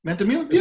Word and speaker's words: Ventimiglia: Ventimiglia. Yeah. This the G is silent.